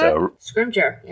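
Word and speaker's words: Scrimgeour: Scrimjaw.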